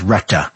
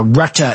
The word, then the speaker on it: Rutte: And just remember, currently NATO Secretary General Mark Rutte, I think it's pronounced Retta, welcome the remarks.